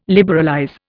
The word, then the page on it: liberalize: Indian